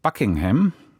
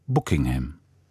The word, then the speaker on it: Buckingham: Der Labour-Mann sagte nicht Buckingham, wie es im Westminster hochenglisch ausgesprochen wird, sondern in seinem nordenglischen Provinzakzent Buckingham.